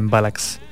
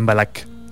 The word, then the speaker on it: Mbalax: conocida como el Mbalax, o Mbalax, también según la pronunciación.